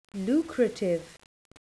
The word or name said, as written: lucrative